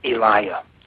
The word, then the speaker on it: Eliah: and the name He gave me was Eliah. – Eliah, how do you spell it? – E-L-I-A-H.